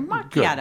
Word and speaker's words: macchiato: Is it macchiato? Well, it's because wasn't there an actor? – Yes, pronounced macchiato. – Yeah, Ralph Macchio. – No, it's a hard K. – Okay. – It's a macchiato.